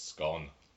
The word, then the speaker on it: scone: Do you pronounce it scone or scone? – It's scone.